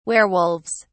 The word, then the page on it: werewolves: British English